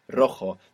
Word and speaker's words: rojo: This is spelled R-O-J-O. And J's in Spanish are pronounced J, like this. Rojo. – Rojo. – And we have the initial R, so if you can do it, it will be the rolling R. Rojo.